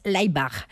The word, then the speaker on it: Laibach: Bueno, creemos que la pronunciación correcta es Laibach.